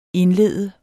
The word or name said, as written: indlede